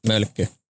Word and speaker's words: Mölkky: Mölkky.